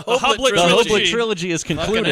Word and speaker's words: Hoblit: I'm calling him Hoblit because it rhymes with Hobbit.